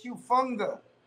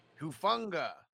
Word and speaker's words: Hufanga: Is it Talon Hufanga? – Hey, mate, it's Hufanga. – Hufanga.